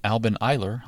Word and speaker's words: Eiler: it's called Alban Eiler or Alban Eiler. It's spelled E-I-L-E-R. And Alban Eiler, I'll say it that way, Alban Eiler means the light of the earth.